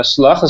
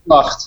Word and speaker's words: Slagt: So how you say it is that Benjamin Slacht is S-L-A-G-T. – Yeah. But you're in Holland, you say Slacht.